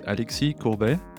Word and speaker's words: Alexis Courbet: So my name is Alexis Courbet, as it's pronounced in French.